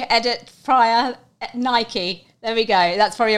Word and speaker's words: Nike: It is actually pronounced Nike, and that is the correct.